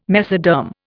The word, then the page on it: mesoderm: Indian